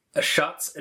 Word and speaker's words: Ashat: This will be Eberron, Ashat's Adventurers. – Ashat's Adventurers. – It's pronounced Ashat. – Pronounced like Shat? – Yeah.